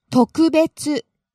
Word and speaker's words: 特別: 特別特別 Now I'm really starting to get the hang of this because I know you started out low there and went high on the second syllable and stayed high.